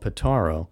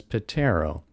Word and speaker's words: Pitaro: Commonly called Pitaro, an ESPN PR representative noted Jimmy says Pitaro, so I will follow suit.